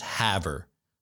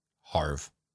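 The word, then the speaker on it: Havre: The town is pronounced Haver. So I apologize to the entire population of Haver, Montana, for mispronouncing the town's name. Again, it's spelled like Farve with an H. So I pronounced it Harve. It's Haver.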